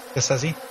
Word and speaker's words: Scorsese: Scorsese? – Yeah.